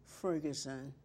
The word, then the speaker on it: Ferguson: My first name is Leona Ferguson. Leona Haslam Ferguson. First name, L-E-O-N-A. Haslam, H-A-S-L-O-M. Ferguson, F-E-R-G-U-S-O-N.